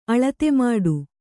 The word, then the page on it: ಅಳತೆಮಾಡು: aḷatemāḍu